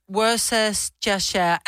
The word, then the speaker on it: Worcestershire: How is Worcestershire actually pronounced? It's pronounced Worcestershire.